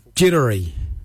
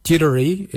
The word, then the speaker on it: djerdari: Jittery. Jittery is spelled D-J-E-R-D-A-R-I. Jittery, brown, jittery.